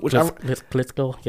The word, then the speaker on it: Klitschko: Klitschko? – Klitschko, yeah.